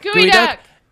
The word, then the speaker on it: Geoduck: I have it in here as Geoduck, because that's how it's spelled. – Gooey duck. – Gooey duck.